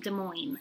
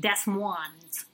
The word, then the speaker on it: Des Moines: I hope they were just having a funny, because it's pronounced Des Moines. It's a major U.S. city. It's not pronounced Des Moines.